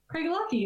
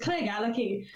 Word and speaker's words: Craigellachie: So one of the owners at Whiskey Nailment, he called it Craigellachie for many, many years until he went there, and they're like, what you call it? And he's like, oh, Craig Lucky. And they're like, it's Craig Alucky. And he was like, oh, shit. I've been saying it wrong for years.